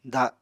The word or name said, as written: da